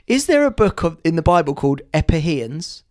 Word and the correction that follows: Ephesians: It's called Ephesians. You pronounced it wrong.